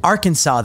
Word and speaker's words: Arkansas: Arkansas, though, because they say, they say Arkansas. Because it's spelled like Kansas, but with the AR in front of it.